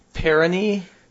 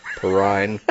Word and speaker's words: Perrine: Yeah, sometime Grigorovich Perini? Pellini? Perini? – Perine. – No, that's not it. – That can't possibly be it. – Perine, it's now Perine. I'm fine you making fun of me, but pronounce it right, damn it.